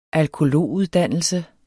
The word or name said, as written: alkolog